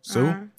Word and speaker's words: Suh: So in February, I can't say her name, but I know her last name is Sue, S-U-H, Sue.